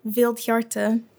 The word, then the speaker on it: Vildhjarta: Vildhjarta, which means wild heart in Swedish.